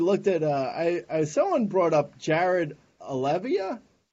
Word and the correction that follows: Oliva: I might be saying the last name wrong. – Oliva? – Oliva, yeah.